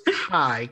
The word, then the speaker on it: Kei: Now, look, I thought his name was Kel, but his name is, I believe it's pronounced Kei, K-E-I.